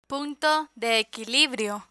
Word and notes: punto de equilibrio: Spanish

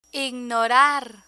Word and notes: ignorar: Spanish

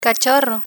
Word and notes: cachorro: spanish

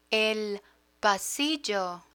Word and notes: el pasillo: Spanish

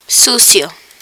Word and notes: sucio: Spanish

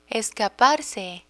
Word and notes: escaparse: Spanish

